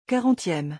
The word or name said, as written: 40eme